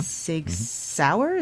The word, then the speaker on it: Sig Sauer: Smith & Wesson, Sturm, Ruger & Company, and Sig Sauer.